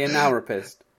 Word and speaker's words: analrapist: Yeah, the analrapist. – Analrapist. – Yeah. You go as if you're going to say analysis, and then you change it to rapist at the end, so it's analrapist.